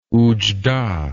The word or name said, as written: oujda